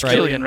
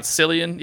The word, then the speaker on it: Cillian: You pronounce it as Killian, right? Not Cillian, even though it's a C.